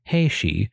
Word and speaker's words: Heishi: Taira itself is a single kanji, so the phrase Taira clan is a compound of two kanjis pronounced as Heishi, and the phrase Taira house is thus pronounced Heike.